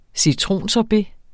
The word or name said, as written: citronsorbet